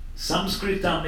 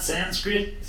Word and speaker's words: Samskritam: Samskritam, it's not Sanskrit. Sanskrit is a mispronunciation.